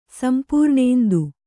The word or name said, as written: ಸಂಪೂರ್ಣೇಂದು